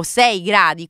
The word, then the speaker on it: Sei gradi: Ci racconti un po' Sesto o Sei Gradi, come si legge bene? Sei gradi.